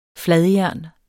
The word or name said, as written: fladjern